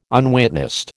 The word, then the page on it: unwitnessed: American English